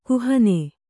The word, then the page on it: ಕುಹನೆ: kuhane